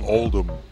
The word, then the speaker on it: Oldham: Oldham. – Without the H pronunciation.